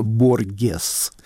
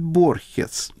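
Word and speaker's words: Borges: В Киеве его очень смешно, его имя произносили смешно, Боргес. Ну, потому что далеко, потому что читают по-испански, но не знают, что нужно говорить Борхес.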